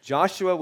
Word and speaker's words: Joshua: Joshua is not three syllables. Joshua was clothed with filthy garments and was standing before the angel.